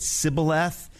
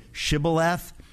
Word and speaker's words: shibboleth: And by the way, that is a shibboleth. A shibboleth in the Old Testament, there's a moment where the people who are from the place called Shibboleth, they pronounce it Sibboleth, and everybody else pronounces it Shibboleth.